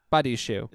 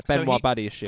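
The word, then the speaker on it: Badiashile: It was that Badashelli or Badiashil. – Badiashil. – Is that how you pronounce it? – That's how you pronounce it, yeah. Benoit Badiashil.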